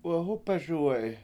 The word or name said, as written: wehUpeSuwe7i